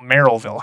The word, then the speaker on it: Merrillville: Excuse me, I said Marionville, Merrillville High School.